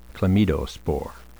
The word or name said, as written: chlamydospore